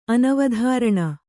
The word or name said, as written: ಅನವಧಾರಣ